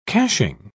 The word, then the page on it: caching: British English